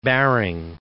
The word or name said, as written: Baring